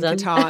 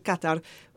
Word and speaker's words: Qatar: It was in, I'm going to say it properly, it was in Qatar, because I keep saying Qatar and getting told off. It was in Qatar with Qatar Airways.